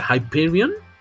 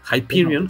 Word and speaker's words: Hyperion: Hyperion. Hyperion. – Hyperion, que es Hyperion pues, dicen en español, pero es Hyperion.